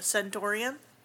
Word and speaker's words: Centaurium: I really liked the old name, the Centaurium, rhymes with Emporium.